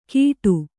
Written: ಗೀಟು

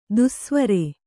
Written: ದೆಸೆವರಿ

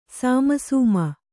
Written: ಸಿಮಿಸಿಮಿ

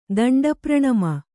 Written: ದಂಡಪ್ರಣಾಮ

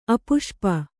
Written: ಅಪುಷ್ಪ

